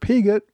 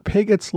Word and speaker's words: Pigot: And while I'm at it, I, in some earlier episodes, mispronounced General Robert Pigott's last name as Pigot, which is the French pronunciation since the family's been living in Britain for several generations, it's become Pigot, and I should have known that.